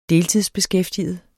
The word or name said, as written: deltidsbeskæftiget